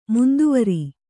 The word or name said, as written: ಮಂದವಾರ